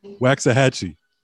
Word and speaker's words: Waxahachie: I was going to try to pronounce the town again, but help me out with that. – Waxahachie.